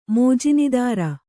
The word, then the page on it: ಮೋಜಿನೀದಾರ: mōjinīdāra